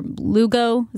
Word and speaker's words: Lugo: They got that new pitcher, Lugo.